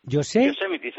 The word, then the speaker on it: Yosemite: Yosemite se pronuncia aquí. Vale, Yosemite.